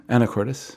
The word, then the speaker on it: Anacortes: He's on an interview I don't know how to pronounce this, Anacortes, Washington? – Anacortes.